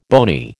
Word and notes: bony: American English

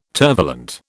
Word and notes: tervalent: British English

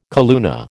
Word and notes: calluna: American English

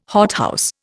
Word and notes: hothouse: Indian

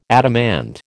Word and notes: adamant: American English

